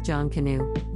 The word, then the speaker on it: Jonkonnu: Though Jonkonnu, pronounced Jonkonnu, was